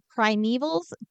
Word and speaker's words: primeval: So the primevals, probably putting the wrong emphasis on the wrong syllable again. Have you heard anything about this movie before? – No, but I do know that the word is Okay. – primeval. You are correct.